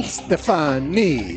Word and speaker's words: Stefani: Gwen Stefani? Stefani. – Why don't you spell it? – S-T-E-F-A-N-I.